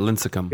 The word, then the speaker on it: Lincecum: Tim Lincecum got the, and I guess they call it Lincecum because I just watched a video with him and one of his lifelong friends, and she kept calling him Lincecum. – So yeah, that's how they say it, Lincecum.